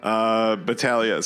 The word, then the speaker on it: Battaglia: So I'm Woody Battaglia. My last name is spelled Battaglia. You got a silent G in there, just like lasagna. Battaglia.